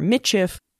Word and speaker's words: Michif: Yeah, so the name of this people in this language is Métis or Michif, which comes from a local pronunciation variant of the word Métis.